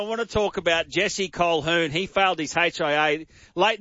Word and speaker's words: Colquhoun: Big man, does it annoy you that Jesse Colquhoun, you pronounce it Colquhoun, but it's spelt C-O-L-Q-U-H-O-U-N.